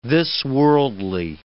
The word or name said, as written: this-worldly